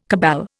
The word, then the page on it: cabal: Indian